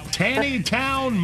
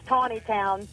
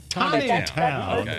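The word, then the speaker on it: Taneytown: Tanny Town, Maryland. Is that you? Where you are? – You know, You could just ask. – Yeah. It's pronounced Tawny Town. Tawny Town.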